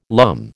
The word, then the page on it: lumme: American English